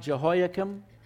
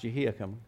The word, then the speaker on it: Jehoiakim: So now we're told in the third year of the reign of Jehoiakim, king of Judah, I know it looks like Jehoiakim, but it's actually pronounced Jehoiakim.